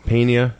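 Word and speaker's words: Peña: Pena, his wife, because there was a scene where he talked to his wife, but I don't know.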